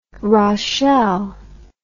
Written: raschel

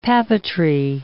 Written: papeterie

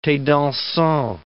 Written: the dansant

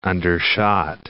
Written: undershot

